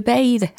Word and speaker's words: bathe: free to scream, 자유로이 소리 지르고 싶고, 그 다음에 free to bathe 라고 했습니다. bath가 아니고 뒤에 e가 붙어가지고 동사 원형으로 어, 여기서 발음을 하셔야 되는 거예요.